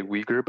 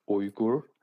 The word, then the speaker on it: Uyghur: So people say Uyghur, but the actual pronunciation is Uyghur. There's I don't know how to say it in English, but there is a bit of a oomph at the beginning. So Uyghur.